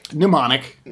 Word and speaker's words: Mnemonic: Mnemonic. – There's, Mnemonic. – It's the M is silent.